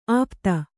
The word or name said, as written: ಆಪ್ತ